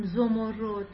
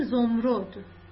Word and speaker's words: زمرد: اینجا به خاطر اینکه وزن شعرمون، ریتم شعرمون خراب نشه، نمیخونیم زمرد، میگیم زمرد، فقط برای اینکه شعرمون قشنگتر خونده بشه. اما تلفظ خود کلمه زمرده.